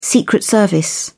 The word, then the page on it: secret service: British English